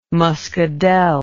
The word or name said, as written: muscadel